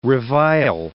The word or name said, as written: revile